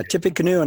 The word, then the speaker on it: Tippecanoe: And I hope I pronounced that right. Tippecanoe. I don't know if that's right. – Yes, yeah, Tippecanoe. – Yeah, Tippecanoe and Tyler, too.